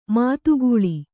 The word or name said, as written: ಮದಕಲ